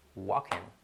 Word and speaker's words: walking: lo pronuncian walking. Es tan sencillo como acordaros del nombre Joaquín, ¿ok? Hola Joaquín, ¿qué tal? A Joaquín le quitas la J y queda, te deja casi ahí, walking, ¿ok?